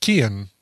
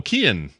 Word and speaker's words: Cian: By the way, C-I-A-N, we're getting corrections, is pronounced Kian. – Oh, Kian. Okay. – Kian. The guy from Peaky Blinders is obviously he's not Kian, but it's also like a hard C as opposed to a soft C.